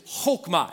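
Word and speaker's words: chokmah (חכמה): The Hebrew word for wisdom is chokmah.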